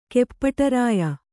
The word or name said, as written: ಕೆಪ್ಪಟರಾಯ